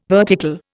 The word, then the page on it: vertical: Indian